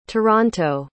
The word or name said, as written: toronto